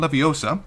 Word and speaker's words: Leviosa: First one, with respect to, and correct me if I'm wrong about my pronunciation, Wingardium Leviosa. – Mm-hmm. I feel like you need a longer degree... O. Leviosa.